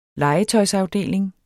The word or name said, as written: legetøjsafdeling